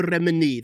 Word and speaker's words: Remini: Okay, so Leah Remini, is that how I pronounce her last name? Leah Remini, that's correct, is speaking out against Tom Cruise and his involvement in the Church of Scientology, claiming there's more here than meets the eye.